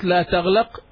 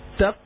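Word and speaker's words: تقلق: It's not la taglaq, it's la taqlaq. – La taqlaq. That's it.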